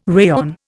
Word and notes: rayon: Indian

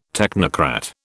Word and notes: technocrat: British English

